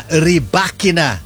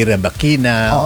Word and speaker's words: Rybakina: And yes, it's pronounced Elena Rybakina. Hindi Rybakina.